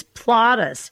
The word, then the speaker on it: Plotus: I better spell it, because if I say it wrong, it's going to sound wrong. He's Plotus, P-L-O-T-U-S, and she's Flotus, F-L-O-T-U-S. I'm not going to put an A there instead of the O, but you get Plotus and Flotus is Ronald and Nancy.